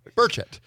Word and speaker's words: Burchett: The emphasis is on it's just one syllable. Burchett.